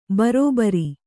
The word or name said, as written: ಭರಭರ